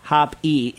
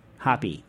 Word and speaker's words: Hoppy: Stephen Hoppy, he says last name pronounced Hop-E, if you care to know, which I do. I like that, Hoppy. But like Hop, like with a capital E, which I also like. Like because when it's, if it's H-O-P-E, that the capital E means it's pronounced E like easy E, right?